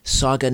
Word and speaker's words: Sauga: Eastern on News Talk Saga 960 AM. News Talk Saga 960 AM. And you can stream it live at saga960am.ca. And Saga, as in Mississauga, is spelled S-A-U-G-A, S-A-U-G-A, Sauga 960 AM.ca.